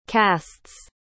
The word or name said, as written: castes